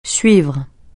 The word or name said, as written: suivre